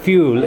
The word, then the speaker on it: Fugl: It's pronounced Fugal. It's spelled F-U-G-L, which is a Norwegian name.